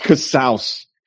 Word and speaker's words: Casaus: And it is pronounced Casaus. – Like house.